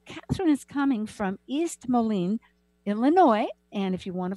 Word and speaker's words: Moline: She teaches astrology and tarot at Blackhawk College, Moline, and I hope I'm saying that right. – Moline, yeah. – Moline, okay, Illinois.